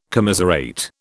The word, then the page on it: commiserate: British English